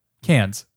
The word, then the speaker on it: Cannes: Cannes. – Cannes. – He goes to Cannes.